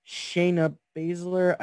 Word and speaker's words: Shayna Baszler: Shayna Baszler. I think I pronounced that wrong. – Correct. No, you hit it right. You got it right.